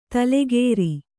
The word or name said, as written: ತೈಲಕಾರ